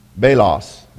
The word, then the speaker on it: belos: It's called belos. It's probably belos. It's probably how you say it. B-E-L-O-S.